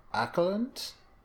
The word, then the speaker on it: Åkerlund: So this was directed by, and I'm probably going to mispronounce this, but I assume it's along the lines of Jonas Åkerlund. – Close enough.